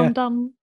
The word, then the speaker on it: Lundon: Or London spelled with L-U-N-D-O-N. So it's spelled like differently. But I think it is London still. – Yeah. London.